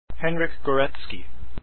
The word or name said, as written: gorecki